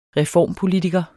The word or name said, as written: reformpolitiker